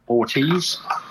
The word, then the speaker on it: Ortiz: Ortiz.